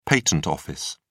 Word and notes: patent office: British English

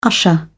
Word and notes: usher: British English

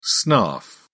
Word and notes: snarf: British English

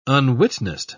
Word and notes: unwitnessed: British English

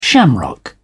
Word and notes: shamrock: British English

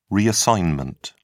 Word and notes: reassignment: British English

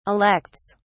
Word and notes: elects: British English

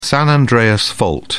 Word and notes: san andreas fault: British English

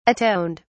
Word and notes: atoned: British English

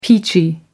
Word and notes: peachy: American English

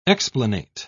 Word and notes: explanate: British English

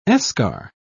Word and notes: eschar: British English

